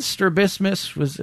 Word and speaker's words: strabismus: He had strabismus. Am I saying that right? – Yep. Mm-hmm.